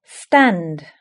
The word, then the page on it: stand: British English